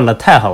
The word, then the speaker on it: LaTeX: Tax 呃，那个发音好像叫 LaTeX，我记得。它虽然它虽然最后是个 X，然后那时候因为也没有它没有什么编辑器嘛，当时因为数学系也不写代码本来，所以那时候就搜了一下，说 LaTeX 应该用什么东西写，然后就搜到了一个说 Vim，一个说 Emacs。